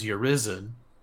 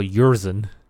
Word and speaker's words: Urizen: Just call Urizen. – Isn't that how it's pronounced? – Urizen. You just kind of ate the I or whatever.